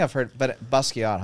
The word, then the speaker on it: Basquiat: Is that how you pronounce it? Okay. Basquiat.